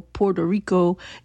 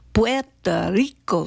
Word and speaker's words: Puerto Rico: And I feel like you need to roll the R's a little more when you pronounce it. It ain't no Puerto Rico, it's Puerto Rico, suave.